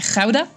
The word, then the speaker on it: gouda: On prononce pas gouda, mais rauda. J'en ai fait un dessin aussi.